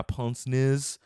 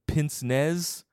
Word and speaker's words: pince-nez: Pince-nez, like it's French, I think.